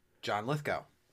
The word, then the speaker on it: John Lithgow: John Lithgow.